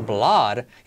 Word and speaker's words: blood: Es una doble O y es blood. Y suena más como una A. Es como come, some, fun. Este sonido, fun. Fun is fun, blood, blood, blood.